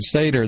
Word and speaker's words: Seder: All right, Seder, that's right. That's dinner. That's a Passover dinner. I'm sorry. It looks like Seder, Cedar. It looks the same. Spelled the same.